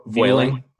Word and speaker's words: Vueling: I don't know how to pronounce it. – Vueling. Vueling. Vueling. Okay.